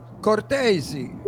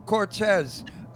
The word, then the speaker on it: Cortese: His name is Cortese. And he was calling you Cortez. I said, Cortez is Mexican. Cortese is Italian.